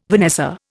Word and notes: vanessa: Indian

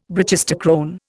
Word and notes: brachistochrone: Indian

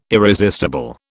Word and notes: irresistible: British English

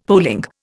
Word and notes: polling: Indian

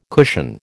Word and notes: cushion: American English

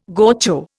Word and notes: gaucho: Indian

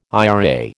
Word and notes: IRA: American English

